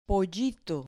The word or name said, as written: pollito